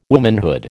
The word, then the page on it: womanhood: American English